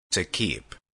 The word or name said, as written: retain